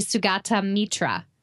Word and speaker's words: Sugata Mitra: His name is, if you looked at it in English and pronounced it as a person from America, it would be Sugata Mitra. So in 1999, he was working in New Delhi. Mm-hmm. So I'm pronouncing that right? – Yeah.